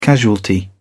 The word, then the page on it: casualty: British English